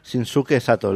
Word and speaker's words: Shinsuke Sato: Shinsuke Sato, ¿lo he hecho bien? – Maravilloso.